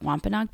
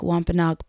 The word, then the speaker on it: Wampanoag: So I read something created by indigenous peoples, but who weren't Wampanoag people, that said to pronounce the name Wampanoa. So I made all of the episodes saying Wampanoa. And then afterward, when I was done recording them, I listened to a Chappaquiddick Wampanoag person named Carrie Roselle Smith say the same word, but she pronounced it Wampanoag.